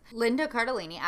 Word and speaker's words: Linda Cardellini: Linda Cardellini.